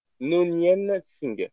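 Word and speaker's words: Khan Noonien Singh: Alors, son nom, je vais en chier pour le prononcer, c'est Khan No. Nonien Singh, c'est bon? – Ouais, je t'en ai bien sorti.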